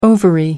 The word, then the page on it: ovary: American English